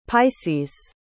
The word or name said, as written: pisces